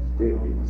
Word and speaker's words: Stalin: Stalin.